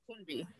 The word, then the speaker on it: Kunbi: Kunbi.